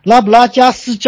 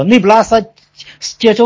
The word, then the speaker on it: 内布拉斯加: 拉布拉加斯州啊，这个发音我可能不太准，嗯，叫内布拉斯加州的时候，它的首府堪萨斯这个地方，这个地方是一个新开发的。